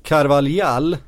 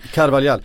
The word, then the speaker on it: Carvalhal: På tal om att komma in rätt, Carvalhal, som ett uttal jag har koll på. Carvalhal.